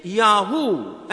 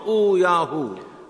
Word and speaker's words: Yahu: Because the real sacred prayer is Yahu and not O Yahu.